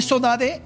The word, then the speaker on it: sodade: Forma de decir sodade, una palabra que en Brasil conocemos mucho, saudade, saudade, es un término portugués que así se pronuncia en el archipiélago.